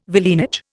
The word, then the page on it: villeinage: Indian